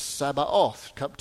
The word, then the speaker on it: Sabaoth: Now, I didn't mispronounce that. That's not Sabbath. That's Sabaoth. Two different words here.